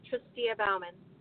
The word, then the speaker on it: Tristia Bauman: And Tristia, if I hope I hope I'm pronouncing your name correctly. – That's right. It's Tristia Bauman.